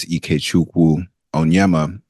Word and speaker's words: Ikechukwu Onyema: And so, yes, as you said, as you pronounced so eloquently just a few moments ago, my full name is Ikechukwu Onyema, and Ikechukwu does mean the power of God.